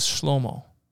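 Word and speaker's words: Shlomo (שלמה): Solomon in Hebrew pronounced properly is Shlomo.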